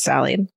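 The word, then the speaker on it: Sali: My great grandmother, Sally, it's S A L I, but then she tells me it's pronounced Sally, was an OG badass.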